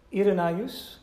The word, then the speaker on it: Irenaeus: Irenaeus, that's how you pronounce his name.